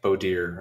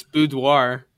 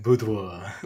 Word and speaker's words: boudoir: I think it's boudoir. – Oh really? I've heard both. That sounds like the French way of saying it, boudoir.